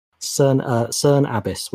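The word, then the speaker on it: Cerne Abbas: We have a CERN Abyss giant. Yeah, Google the CERN Abyss giant sometime. It's a good laugh. CERN Abyss, which is spelled CERNE space ABBAS.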